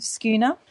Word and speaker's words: Skåne: So I found them in the province of Skåne. And it's spelled S-K-A-N-E.